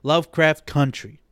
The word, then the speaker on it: Lovecraft Country: But basically what this show is about, Lovecraft Country, I keep mispronouncing it because I keep wanting to say county, but it's country. Lovecraft Country.